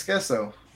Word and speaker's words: queso: You guys don't even pronounce it right. It's queso.